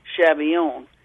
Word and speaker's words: Schabillion: My maiden name was Chabillion, spelled Schabillion. It heralded from Alsace-Lorraine and was actually pronounced Chabillon.